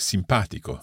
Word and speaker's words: simpatico: I'm also thinking of an adjective like simpatico. – Simpatico. So if we divide this adjective in syllables, the stress is on pa. Simpatico. So the stress is not on the second to last syllable.